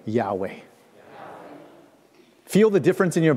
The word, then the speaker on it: Yahweh: Yahweh requires you to open up your throat, to drop your jaw, and exhale without restriction.